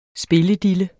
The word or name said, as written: spilledille